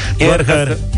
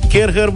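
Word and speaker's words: Kärcher: ai putea să câștigi unul dintre aparatele astea de la Karcher. Kerher. Kerher, măi, nene. Kerher, tati, așa.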